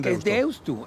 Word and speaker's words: Deusto: ¿Qué haces con Deusto? – Es Deusto, porque se escribe con U final.